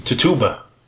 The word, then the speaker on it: Tituba: That's Tabula. She was like the Tabula. I can't remember how to pronounce her name. – Tituba. – It's not a Tituba.